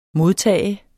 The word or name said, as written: modtager